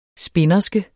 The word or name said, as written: spinderske